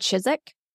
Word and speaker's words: Chiswick: You know, like I was going to say Chiswick, so I'm glad you said Chiswick. Save me that embarrassment later. – Yeah, it's spelled Chiswick, but we live in England, so we just randomly choose which letters we're going to enunciate.